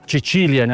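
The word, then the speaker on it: Cecilia: Но е композирано по повод на Деня на света Чечилия, някой го казват, нали, с италианско произношение, светицата покровителка на музиката.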